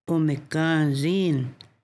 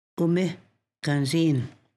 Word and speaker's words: umikanzin: The word should not be pronounced as umikanzin. The A is nasal, umikanzin.